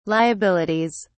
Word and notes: liabilities: British English

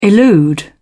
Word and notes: elude: British English

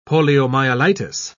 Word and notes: poliomyelitis: British English

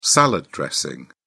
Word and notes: salad dressing: British English

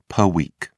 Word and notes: pw: British English

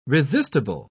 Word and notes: resistible: British English

